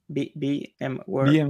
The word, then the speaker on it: VMware: Sí, eh, eh, VMware Tools, ¿vale? – Ya, ya ofrece. ¿Cómo? perdona, que va a pronunciar. VMware.